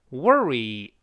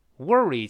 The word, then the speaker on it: worry: 英式发音的前面发啊啊，美式发音的 o r 直接在一块发 er wor。 Worry, worry，前面是直接勾式的 er，我们再读一下 worry, worry。